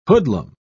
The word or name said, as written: hoodlum